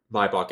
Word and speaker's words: Maybach: Designed to be even more exclusive than the standard Maybach S-Class, I almost said Maybach there. And hey, Maybach, Maybach, what do you think? I think Maybach is how they say it in Britain, and I've been watching a little bit of Top Gear recently, so there's the explanation for that.